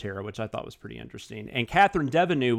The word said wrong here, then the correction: Deneuve: was actually considered for her as well at one point, but then they ended up going Catherine Deneuve? – Deneuve, sorry. God, I always say that wrong.